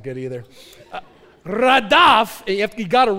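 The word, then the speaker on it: radaf (רדף): how to pronounce it so you don't look stupid. It's radaf.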